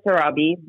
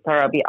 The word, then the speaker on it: Sarabi: Well, my Instagram is Golsa Sarabi. My Facebook is Golsa Sarabi Official.